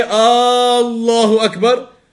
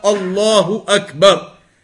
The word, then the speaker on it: Allahu Akbar: So you don't say Allahu Akbar, and neither do you say Allahu Akbar. Extending it in that way is not correct. But rather a person pronounces it and says it in the proper manner, Allahu Akbar.